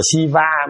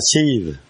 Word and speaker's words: Shiva: Shiva, nó không đọc là Shiva, mình đọc là thần Shiva, nó đọc là Shiva.